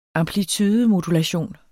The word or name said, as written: amplitudemodulation